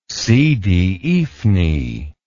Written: sidi ifni

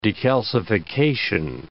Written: decalcification

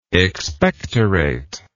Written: expectorator

